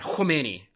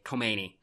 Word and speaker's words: Khomeini: But Khomeini, or as Americans say, Khomeini. my pronunciation was bad in both cases.